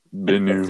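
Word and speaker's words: Bennu: Try to pronounce this one more time correctly. – Bienu. – Thank you.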